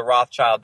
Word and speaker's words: Rothschild: Well, the thing is, Rothschild, the original founder of the Rothschild dynasty's name was Meyer Bauer, and he changed his name to Rothschild, is how you pronounce it in German, which, yeah, it means red sign.